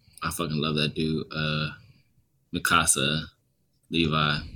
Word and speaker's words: Mikasa: I love Misaka and Hanji. Is that how you pronounce his name? I can't remember. – Mikasa and Hanji.